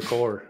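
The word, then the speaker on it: corps: No, in my mind, I just said wide receiver core. – Yeah. Corpse. Yeah. Core.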